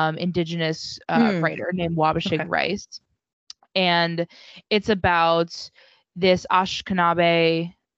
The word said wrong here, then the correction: Anishinaabe: Sorry, I think I mispronounced it. – Ashkenazi? – No. Anishinaabe. I'm not.